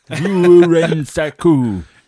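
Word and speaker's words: Vuorensaku: It's V-U-O-R-E-N-S-A-K-U. Guitars. – Do you want to spell that phonetically? – No, that's fine. Vorensaku.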